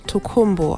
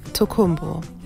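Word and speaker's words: Tokunbo: Aber es wird auf Deutsch Tokumbo ausgesprochen. Mit M. Tokumbo.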